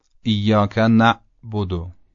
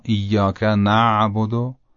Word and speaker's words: ایاک نعبد: تلفظ غلطش رو اول انجام میدم، بعد تلفظ صحیحش رو. ایاک نعبد، این غلطه. و اما ایاک نعبد، ایاک نعبد، این صحیح و درسته.